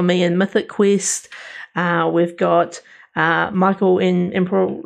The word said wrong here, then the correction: Imperioli: Michael Imperioli. – Imperioli uh, Christopher.